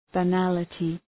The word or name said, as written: banality